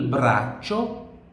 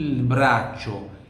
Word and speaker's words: braccio: Por que que é braccio? CI tem som de TCHI. Eu vou escrever aqui, ó. CI tem som de TCHI. Braccio.